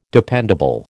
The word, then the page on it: dependable: American English